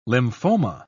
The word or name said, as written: lymphoma